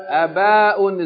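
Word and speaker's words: Aba'un (آباء): So we pronounce this word with a two-count fatta, two-count med. And on the first letter, a, and then ba fatta aleph, ba hamza tanween. Aba'un. Aba'un is the plural of ab.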